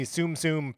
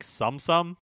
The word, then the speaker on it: Tsum Tsum: I always thought it was like sum sum, these are the like uh, fat stuffed animal versions of Disney characters.